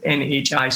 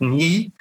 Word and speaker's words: Nhi: And I actually said it right on the podcast because it's NHI, so it's Nhi. And she introduced herself as Nhi.